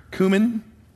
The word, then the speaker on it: cumin: They would get their mint and their anise and their cumin. What's cumin? Do you guys have cumin in your spice? Yeah, okay. So all these little spices they get. Am I saying it right? Cumin? Yeah. Okay, good.